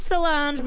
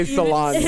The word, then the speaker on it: Solange: It's pronounced Solange. – Solange. Oh, I always say Solange.